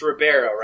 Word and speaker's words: Ribeiro: It's Ribeiro, right? I'm always worried I'm going to pronounce it wrong. – I believe that is correct pronunciation.